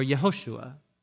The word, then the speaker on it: Yehoshua: In Aramaic or in Hebrew, the way you pronounce his name is either Yeshua as a short form, or Yehoshua is a long form where we get Joshua, Yeshua or Yehoshua.